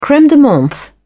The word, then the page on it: creme de menthe: British English